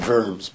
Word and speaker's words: Worms: But it's spelled with a V, Worms. But anyway, that's right.